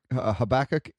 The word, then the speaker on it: Habakkuk: Habakkuk is the traditional American English pronunciation of it.